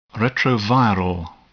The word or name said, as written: retroviral